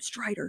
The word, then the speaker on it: Strider: I always feel like I have to pronounce his name the way Sam pronounces his name, Strider.